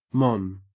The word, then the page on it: mon: Australian English